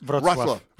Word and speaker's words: Wrocław: Wrocław.